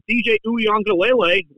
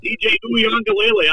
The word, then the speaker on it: Uiagalelei: Now, with DJ Uiagalelei, which I said the name right. – Very good. – Yeah, DJ Uiagalelei.